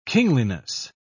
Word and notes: kingliness: British English

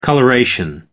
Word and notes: coloration: American English

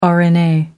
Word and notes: rna: American English

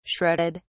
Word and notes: shredded: British English

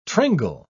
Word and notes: tringle: British English

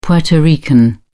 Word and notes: Puerto Rican: British English

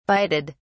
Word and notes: bided: British English

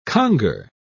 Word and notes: conger: British English

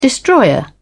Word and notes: destroyer: British English

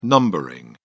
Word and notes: numbering: British English

